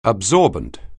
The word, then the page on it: absorbent: anglais